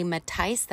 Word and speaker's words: Mattice: Anyway, I'm going to be telling you a little bit about this upcoming episode with my friend Ali Matice. That's how you pronounce it. M-A-T-T-I-C-E.